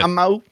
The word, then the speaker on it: Nanaimo: I'm not sure how you say it. – Nanaimo.